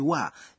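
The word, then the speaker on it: wa (は): Anasema, katika siku za nyuma, wa inayoonyesha mada ilitamkwa na kuandikwa kama ha. Matamshi yake yamebadilika pole pole na kuwa wa, lakini bado inaandikwa kama ha.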